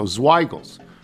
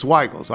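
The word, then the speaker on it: Zweigle's: Zweigels, the creator of the White Hot, Hoffman who, should be said with a long I sound. Okay, rhyming with higher high. So Zweigels. It's Zweigels.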